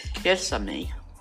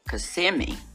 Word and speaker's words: Kissimmee: Kissimmee. Sweet name for a town." My sister said, "It's an Indian name, Mother. It's Kissimmee." Mother said, "No, I think it's Kissimmee."